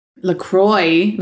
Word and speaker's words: LaCroix: LaCroix, according to Caitlin Bristow. – Oh, LaCroix. – When she does ads for it, she's like, LaCroix, LaCroix. And I'm like, no, it can't be. I've been saying this wrong. – It's LaCroix.